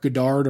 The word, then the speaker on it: Goddard: Referee Marc Goddard or Marc Goddard, depending on your pronunciation, jumps in there to call it off.